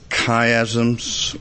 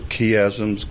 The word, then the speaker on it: chiasms: known as chiasms, or some pronounce it chiasms. Chi is the Greek letter that looks like an X in our language.